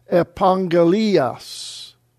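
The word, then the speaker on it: Epangalias (ἐπαγγελίας): When two gammas come together, it's pronounced as NG, Epangalias.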